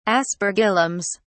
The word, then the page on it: aspergillums: British English